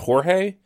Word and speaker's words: Jorge: I'm going to need some pronunciation help here on, is that Jorge? – Yes.